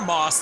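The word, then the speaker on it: Masse: And off the wall, and Kylie Mass or Moss, depending on what kind of pronunciation you have, is still in the lead.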